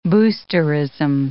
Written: boosterism

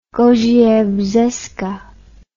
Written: Gaudier-Brzeska

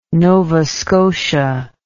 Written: nova scotia

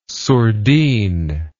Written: sourdine

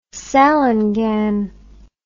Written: salangane

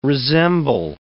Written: resemble

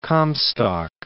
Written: Comstock Lode

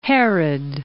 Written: Herod

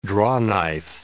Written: drawknife

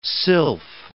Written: sylph

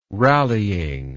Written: rallying

